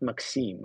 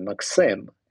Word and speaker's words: Maxim: And I'm like, no, please, I'm not Maxim, I'm Maxim. And this is like a very strong I there.